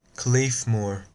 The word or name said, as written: Claidheamh Mòr